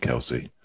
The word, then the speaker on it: Kelce: Please start calling him Kelce. He's a big enough and famous enough player for you to get his name right.